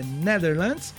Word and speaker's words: Netherlands: Netherlands. Netherlands, muito bem, muito obrigado.